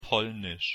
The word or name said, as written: polnisch